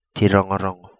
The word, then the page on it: Ti Rongorongo: Kapingamarangi